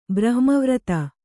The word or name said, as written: ಬ್ರಹ್ಮವ್ರತ